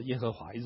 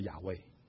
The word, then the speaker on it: 耶和华: 所以我们有时候有首歌叫阿多奈，那阿多奈不是英文，那是希伯来文的发音，他把那个阿多奈的那个音的字呢放到这个字里面来念，就念成有两种念法，一种叫耶和华，一种叫亚威。你如果看一些书里面，他有时候看到说，哎，他怎么不是怎么叫亚威？